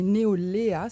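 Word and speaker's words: NEOLAiA: Et Neolea, ça se prononce comme le prénom Léa, ça veut dire jeunesse, en fait.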